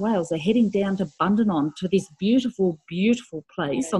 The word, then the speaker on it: Bundanon: Tell me about your residency at, I don't know how to pronounce it, down at Bundanon.